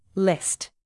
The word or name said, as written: list